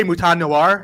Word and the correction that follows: Mouton Noir: But it's pronounced Mouton Noir, almost like mountain.